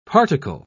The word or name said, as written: particle